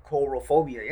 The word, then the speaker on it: Coulrophobia: Coulrophobia. Coulrophobia. – What is that? – That's a fear of clowns. – Yes, you are. – Am I reading that right? – Coulrophobia, yeah. – Coulrophobia. Okay, coulrophobia.